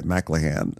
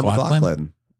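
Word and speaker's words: MacLachlan: I am obsessed with the way you say McLaughlin, I have to say. I've never heard it said mispronounced that way. McLahan. – I thought it was Kyle McLahan. McLaughlin? Wait, how do you say it? – Kyle McLaughlin. Yeah.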